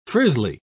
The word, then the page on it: frizzly: British English